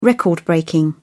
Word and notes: record breaking: British English

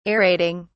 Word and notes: aerating: British English

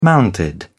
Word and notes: mounted: British English

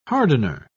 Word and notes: hardener: British English

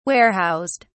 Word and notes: warehoused: British English

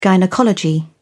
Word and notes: gynaecology: British English

